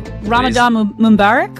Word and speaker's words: Ramadan Mubarak: I was going to say Ramadan Mubarak, or am I saying that right? – You are. Thank you very much.